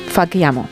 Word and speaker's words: facciamo: Fachiamo. – Solamente un, error que te corrijo desde mi bilingüismo. Vale. La CH es una K. Fachiamo. – Es que se escribe con CC, entonces yo me lo pongo como, como lo he escuchado.